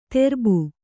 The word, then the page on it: ತೆರ್ಬು: terbu